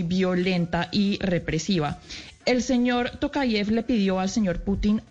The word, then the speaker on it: Tokayev: Esto, sin embargo, se volvió en unas protestas en contra del gobierno de Kazajistán y eh, el señor eh, Takayev, perdóneme que le diga a ver si Tokayev, estoy pronunciándolo bien,